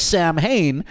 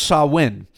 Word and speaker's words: Samhain: Now, it looks like Samhain, but it's pronounced Samhain.